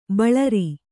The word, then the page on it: ಬಳರಿ: baḷari